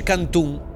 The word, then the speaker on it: canton: Si dice canton, in tutti i canton. – Ragazzi, ma mi viene da piangere. – Qualcuno però dice cantun. Canton.